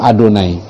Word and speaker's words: Adonai: Jadi YHWH itu bacanya gimana? Mereka akan sebut itu Adonai, ya, my God, Adonai.